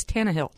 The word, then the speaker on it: Tannehill: It's Tannehill. – Mm-hmm. – So, you know, and I know those of us in the South, we want to add some extra syllables. It's Tannehill.